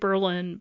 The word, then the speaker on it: Berlin: And the 2011 Berlin Daily Sun story, and by the way, Berlin, New Hampshire is spelled like Berlin, but pronounced Berlin, if it sounds like I'm mispronouncing it.